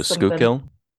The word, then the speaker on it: Schuylkill: Literally all I'm thinking about is this river in Pennsylvania that's named, spelled one way, but pronounced a very different way. It's like Skull. – Oh, Schuylkill? – Yeah, that's all that's coming to mind.